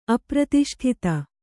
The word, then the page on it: ಅಪ್ರತಿಷ್ಠಿತ: apratiṣṭhita